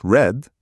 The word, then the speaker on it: read: Read, pronounced read. Read, pronounced red.